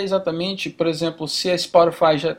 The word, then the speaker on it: Spotify: Que eu nem sei se essa é a pronúncia correta em português, mas Spotify. – É, isso mesmo.